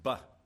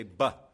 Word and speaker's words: bet: It's the second letter of the Hebrew alphabet, and it's pronounced buh. Okay? Can you say buh?